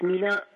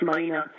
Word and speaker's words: Mina: We looked in France and it's Mina, and in Scotland it's Mina. So you can say it either way. And if someone corrects you, just say, I'm using the French pronunciation.